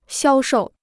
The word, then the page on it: 销售: xiāo shòu